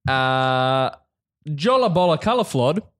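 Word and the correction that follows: Jólabókaflóð: That's not how you pronounce it. – Yes. – Great. – Jolla Bocala Flod. Jolla Bo Ka Flod. There you go. Jolla Bocca Flod. – That's the one.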